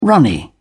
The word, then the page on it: runny: British English